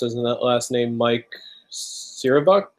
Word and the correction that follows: Cernovich: I think it's Cernovich. – Cernovich.